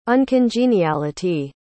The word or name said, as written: uncongeniality